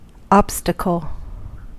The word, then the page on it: obstacle: American English; [ˈɒb.stə.kəl]